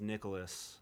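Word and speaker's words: Nicolas: Well, my full name is Nicholas, without an H. It's N-I-C-O-L-A-S.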